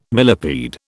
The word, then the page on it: millepede: British English